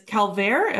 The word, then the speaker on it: Calvaire: So the movie is Calvaire. Am I saying that right? Maybe. – Yep.